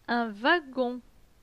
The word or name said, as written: Un wagon